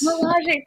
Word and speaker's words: Milaje: Milaje, thank you.